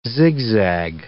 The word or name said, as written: zigzag